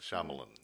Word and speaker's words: Shyamalan: By the way, I know his right pronunciation of his last name now. It's Shyamalan. – Shyamalan. – Shyamalan. – Okay. – Yeah, not Shyamalan.